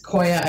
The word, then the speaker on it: Qoya: So, Qoya is a movement system, and it's Qoya.